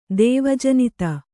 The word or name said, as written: ದೇವಜನಿತ